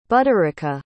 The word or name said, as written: budarika